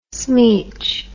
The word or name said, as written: smeech